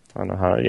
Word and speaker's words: Anohana: I finished watching Anohana. Is that how you say it? – Anohana? – Anohana, yeah.